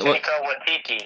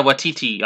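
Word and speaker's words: Waititi: Watiti. – Yeah, Watiti.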